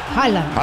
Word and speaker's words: Haaland: Haaland. – Exacto. Y también Erling Haaland, 91. Y Haaland, solamente Haaland. Haaland Ramos, por ejemplo, 468. – Mira, claro, tú. – ¿Cómo se pronuncia? Haaland. – Haaland.